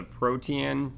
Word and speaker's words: Protean: It's the Protean charm. P-R-O-T-E-A-N, yep.